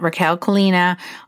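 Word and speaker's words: Rakel Colina: You can send me a DM at Raquel Colina on Instagram if you'd like.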